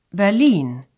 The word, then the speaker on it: Berlin: In German, it's pronounced Berlin.